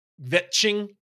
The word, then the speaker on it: kvetching: Vetching? Did I pronounce that correctly? – Plum likes to drop the mensch, so he's probably up on his Yiddish. – Yeah, I think that's right. I think you got it there.